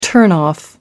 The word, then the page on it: turn off: American English